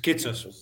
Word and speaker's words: Kitsos: Just Kitsos.